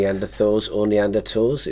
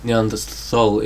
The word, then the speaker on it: Neanderthal: Neanderthals.